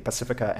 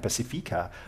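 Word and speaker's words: Pasifika: And just for our listeners who may have picked up on the two different ways in which we pronounce the Pacifica and Pacifica, Tegan is using the Auckland pronunciation. And I'm using the Wellington pronunciation.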